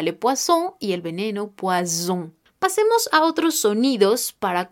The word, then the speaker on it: poison: Así que cuidado al pronunciar esta palabra que será le poison, le poison.